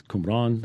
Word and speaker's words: Qumran: It's Qumran. That's how you say it.